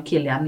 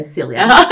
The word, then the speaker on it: Cillian: Det skämtar han också om att när han gör drama så uttalar man Killian. Gör han något humoristiskt så är det Cillian.